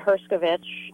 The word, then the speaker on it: Herscovitch: So it's Lara, L-A-R-A, and Herscovitch is H-E-R-S-C-O-V-I-T-C-H.